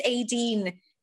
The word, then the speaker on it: Aideen: My name is Aideen. So it's A-I-D-E-E-N. It's like it's phonetic. So Aideen, the E-E is an E. Like, you know, like Queen.